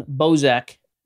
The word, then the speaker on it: Bozak: So let's introduce my buddy, Justin Bozak. As we just found out, it has an S, but it's pronounced with a Z.